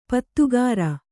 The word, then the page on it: ಪದಗಾರ: padagāra